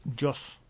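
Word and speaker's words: JAWS: Pero bueno, nos pregunta que si hay un programa para personas ciegas o débiles visuales que se llama Joyce, caballeros. – Joss. – Se escribe J de Julieta, A de Alfa, W de Whisky, S.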